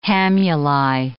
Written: hamuli

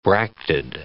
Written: bracted

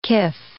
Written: kif

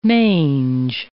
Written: mange

